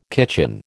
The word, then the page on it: kitchen: American English